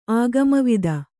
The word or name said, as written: ಆಗಮವಿದ